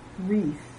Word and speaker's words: wreath: W is silent before R at the beginning of a word. Write. They write a letter every week. Wrap. Can you wrap the package for me? Wreath.